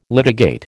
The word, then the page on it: litigate: American English